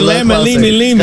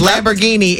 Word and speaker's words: Lamborghini: Closer than lemma, lemma, lemma. – Because Lamborghini is 4 syllables. Lamma, lemma, lemma. You gave 6, baby.